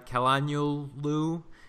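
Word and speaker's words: Çalhanoğlu: The game first, I can never say his name right, Calhanoglu got one here. Cotrone got... – That's right. You nailed it.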